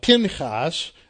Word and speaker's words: Phinehas: Pinchas, as it's pronounced in the Hebrew, has two sons, Ichabod, who was born there just after the Battle of Aphek when they lose the Ark.